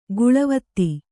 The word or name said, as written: ಕಳಾವತಿ